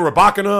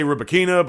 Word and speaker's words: Rybakina: Even also Elena Rybakina. I was saying her name wrong. I used to say Rybakina, but from what I've watched, there's Rybakina. But you can say Rybakina, Rybakina, tomato, tomato.